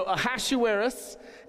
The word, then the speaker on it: Ahasuerus: So Ahasuerus is how you or how we will be pronouncing this king's name.